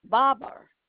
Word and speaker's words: Barber: Y-A-S-U-K-O, that's Yasuko, and the last name is Barber, B-A-R-B-E-R. It's like a barbershop.